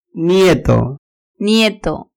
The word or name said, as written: nieto